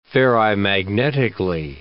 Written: ferrimagnetically

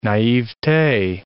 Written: naivete

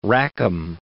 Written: Rackham

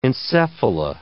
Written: encephala

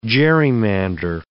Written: gerrymander

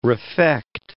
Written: refect

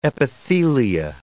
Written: epithelia

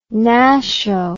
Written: nasho

